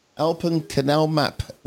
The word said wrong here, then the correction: Open Canal Map: Not Alpen. Don't mispronounce it, otherwise you'll be thinking... – Open Canal Map.